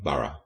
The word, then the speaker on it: Burgh: Enter Sir Edward Burr. Well, no, it's actually not anymore. It's about to change. It's spelled B-U-R-G-H, but we're now saying Burrough instead of Burr.